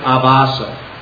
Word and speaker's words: Abhasa: which is called Nam Abhasa. I don't have a thing to mark it up here, but A Nama, and then A-B-H-A-S-A, with two long A's, Abhasa.